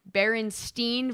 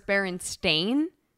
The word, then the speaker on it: Berenstain: I always thought it was Bern versus Baron, but that's not even it. It's apparently Berenstein versus Berenstain. – Okay. And what is it? – Berenstain Bears.